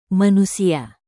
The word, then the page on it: manusia: Indonesian